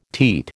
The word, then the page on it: teat: American English